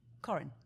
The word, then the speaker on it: Corinne: Corinne.